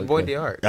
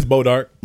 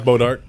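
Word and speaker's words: Bois d'Arc: I thought it was Boys the Ark. – No, I can say it's Boys. It's Bodark. – Bodark. – It's pronounced Bodark. It's French.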